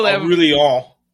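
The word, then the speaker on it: Aurélien: So in midfield, Aurelien, if you're French.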